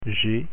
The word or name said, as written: g